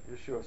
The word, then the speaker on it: Yeshua: Anybody know how you say Jesus in Hebrew? Right, Yeshua.